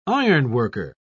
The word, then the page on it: ironworker: British English